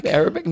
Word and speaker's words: Arabic: I got some aerobic. How do you say that word? – Arabic music.